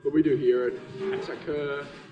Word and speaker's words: Attacker: Like, it comes from a French word, and if you were to pronounce it correctly, it should be attaque, but we're Australian, so we just kind of Australianize it and say attacker.